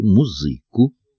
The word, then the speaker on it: музыку: Никому уже не казалось богомерзким и идущим против канонов российского бытия то, что кавалеры танцуют с дамами под завезенную из немцев музыку, хотя ударение в этом новом для Руси слове еще ставили на втором слоге «музыку».